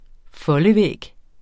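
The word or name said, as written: FOLDEVÆG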